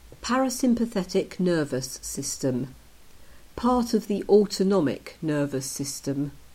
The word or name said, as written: Parasympathetic nervous system